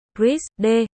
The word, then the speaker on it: RISD: RISD, RZD, phát âm là RISD, là một trường nghệ thuật và thiết kế tư nhân ở Providence.